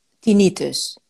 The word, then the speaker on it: tinnitus: Want het doet me denken aan ehm, tinnitus.